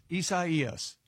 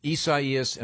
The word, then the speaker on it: Isaias: Said it's it's Isaias, and it's got three different. There's three different pronouncers here. – Yeah, yeah. – From the Associated Press, which is, it, there's like a Mexican, a French, and a Portuguese or something.